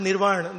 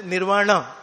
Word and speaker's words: Nirvana: So Americans, they pronounce it, they don't pronounce Nirvana, they say Nirvana, like that.